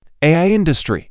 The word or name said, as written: AI industry